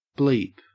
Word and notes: bleep: Australian English

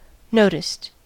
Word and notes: noticed: American English

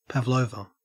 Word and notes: pavlova: Australian English

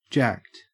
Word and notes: jacked: Australian English

